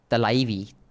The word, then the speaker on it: Thalaivi: You might be thrilled to note that Thalaivi, pronounced Thalaivi, Thalaivi, Thalaivi, will now be available in even Tamil.